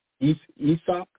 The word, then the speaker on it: Isaac: Some of them called themselves the House of Isaac, which is pronounced Isaac, with the emphasis on the last syllable, Isaac.